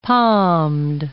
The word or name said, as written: palmed